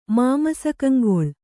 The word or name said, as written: ಮಾಮಸಕಂಗೊಳ್